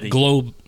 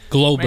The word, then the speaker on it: Globetti: So I've known about specifically the Goldblatt, or hold on, when we say your last name, Goldbetty, because first of all, I thought it was Goldbetty, but y'all just pronounced it totally different. – Globetty.